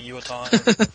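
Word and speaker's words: Uotan: Is that how you pronounce it? – Something like Yuotan. – That. Yeah, Yuotan.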